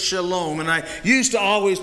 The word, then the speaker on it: shalom: It comes from the Hebrew and is pronounced shalom.